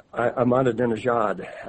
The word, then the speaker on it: Ahmadinejad: The language used by Iranian President I'm going to have difficulty with this because it's Ahmadinejad, I believe the emphasis is on the last syllable there.